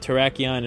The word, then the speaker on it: Terrakion: Terrakion, and Virizion.